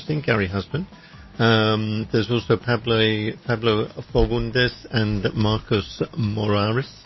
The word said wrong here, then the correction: Moraes: Moraes. Would you pronounce it like that? – Moraes, yeah.